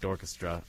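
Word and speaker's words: D'orchestra: Again, Dorchestra is spelled D-apostrophe orchestra, but it's pronounced like Dorchestra.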